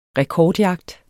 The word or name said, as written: rekordjagt